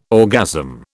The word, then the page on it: orgasm: British English